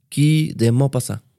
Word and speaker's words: Guy de Maupassant: Guy de Maupassant wow.